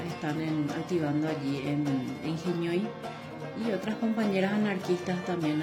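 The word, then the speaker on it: Henói: De Genioi en el Facebook. Genioi se escribe, es una palabra en guaraní. Se escribe H-E-N-O-I latina.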